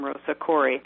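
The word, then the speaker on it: Rosa Koire: My last name is spelled K-O-I-R-E. My first name is Rosa. I'm Rosa Corey.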